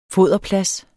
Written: foderplads

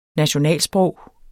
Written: NATIONALSPROG

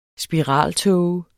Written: spiraltåge

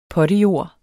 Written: potte

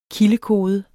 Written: kildekode

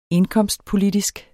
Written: indkomstpolitisk

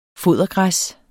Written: fodergræs

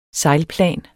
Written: sejlplan

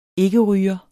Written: IKKERYGER

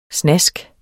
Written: snask

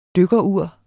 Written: dykkerur

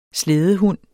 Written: slædehund

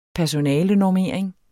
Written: personalenormering

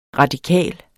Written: radikal

